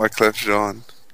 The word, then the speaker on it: Wyclef Jean: How do you say that? Wyclef Jean? – Wyclef Jean. – Wyclef Jean. – Like Jean-Paul Gaultier.